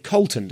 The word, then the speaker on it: Coulton: Recording a podcast with Jonathan Coulton tomorrow now, and have just found out he pronounces it Coulton, despite the presence of a U in there.